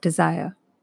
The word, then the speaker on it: desire: Let me talk for a minute about the idea of desire. Desire, that soft, unstressed syllable, de, followed by the stressed syllable, sire, is music to the ears.